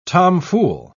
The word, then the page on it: tomfool: British English